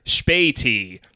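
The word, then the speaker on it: Spätis: Uh, it is called I had to ask him to pronounce it for me because it's a very European name. Uh, Corner Spati, which is spelled S P A with two dots over it, T I S.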